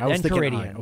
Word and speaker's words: Enchiridion: Yeah, Enchiridion.